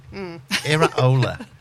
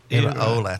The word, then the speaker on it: Iraola: And this is how you pronounce it. – Iraola. – What? – Iraola.